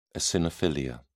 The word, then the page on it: eosinophilia: British English